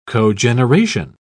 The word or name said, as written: cogeneration